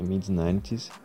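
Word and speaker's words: Mid90s: É Mid-90s, se escreve M I D 90 s tudo junto.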